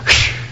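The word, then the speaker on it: Sh (שין): La 21ª letra, o sea, la letra número 21 del alfabeto hebreo, es la letra Sin, así se pronuncia, la letra Sin. Cuando se escribe en forma de W, se escribe como si fuera la letra nuestra W, pero ellos dicen Sin. Ahora, ¿cómo se pronuncia? En el hebreo se pronuncia Sh. Esa es la pronunciación.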